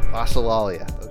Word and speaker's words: Glossolalia: Can you pronounce the name of the album? – Glossolalia. – Glossolalia. Okay, very good.